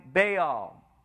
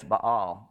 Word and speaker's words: Baal: These prophets, he said, are now prophesying by Baal. The proper, I think, pronunciation of that is Baal, but we all call him Baal.